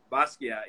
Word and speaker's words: Basquiat: Um, that one of my favorites is the uh, I'm gonna pronounce this wrong, but was it the Basquiat? That what you did? That um. – Yeah, yeah, yeah. – Basquiat, yeah. There it is.